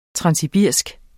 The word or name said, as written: transsibirisk